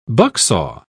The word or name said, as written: bucksaw